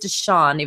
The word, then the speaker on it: DeShone: And DeShone Kizer is now for sure, and it is DeShone, even though it's spelled DeShone.